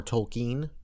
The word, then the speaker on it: Tolkien: Tolkien's novels, or Tolkien is actually the correct pronunciation. I always get that wrong, like, the first time, and then I have to remember.